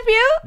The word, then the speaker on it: Pugh: What's her name? Pugh. – You got it. – It's Frances Pugh.